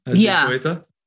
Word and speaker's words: Die Poeta: So you do stuff for example, you have a beautiful, beautiful piece called, you're going to have to tell me if I pronounce it right, Die Poeta? – Yeah. Die Poeta.